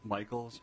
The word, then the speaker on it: Michals: Madam Chair, my name is Rick Michaels. My last name is spelled M-I-C-H-A-L-S, and I am the State Operations Manager with the Health Regulation Division of the Minnesota Department of Health.